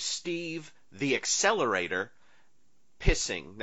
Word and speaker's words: Pising: Now, it's spelled P-I-S-I-N-G, and again, in the DVD credits, he's Esquire. So again, you get a law degree when you go on the show. But he's Steve, it's not pising, yeah. It's not whatever any other pronunciation you can think of, it's pissing.